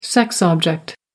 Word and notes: sex object: American English

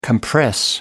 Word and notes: compress: American English

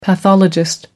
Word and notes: pathologist: American English

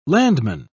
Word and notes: landman: British English

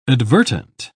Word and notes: advertent: British English

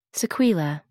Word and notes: sequela: American English

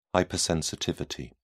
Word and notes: hypersensitivity: British English

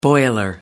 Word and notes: boiler: American English

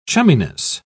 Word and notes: chumminess: British English

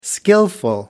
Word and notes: skilful: American English

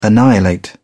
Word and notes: annihilate: British English

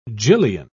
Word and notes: gillion: British English

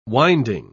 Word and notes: winding: British English

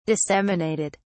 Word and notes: disseminated: British English

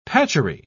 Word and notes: patchery: British English

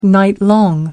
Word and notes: nightlong: American English

